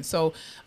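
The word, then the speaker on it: Seay: Yes, but it's S-E-A-Y. I'm probably saying it wrong. I think it's C. It's C. – S. Ebony S.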